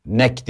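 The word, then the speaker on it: NEC: Nek diye okunuyor, Nek Nijmegen'e.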